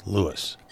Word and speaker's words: Lewes: We and Lewis, the podcast. It takes a little time to learn that the name of Lewis, Delaware, isn't pronounced as it's spelled. It's spelled L-E-W-E-S, lose, but it's pronounced Lewis.